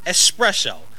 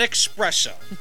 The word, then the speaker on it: espresso: And I pronounce it espresso, not expresso.